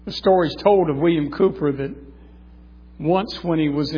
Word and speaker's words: Cowper: William Cooper, one of the great hymn writers of history. Spelled C-O-W-P-E-R, but I understand it's pronounced Cooper.